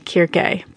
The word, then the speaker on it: Circe: Although, if I want to be really, really technical, the Greek would be Kyrke, but I didn't go with that. – That's good, because I don't know if I could pronounce it. Now, introduce me to Circe.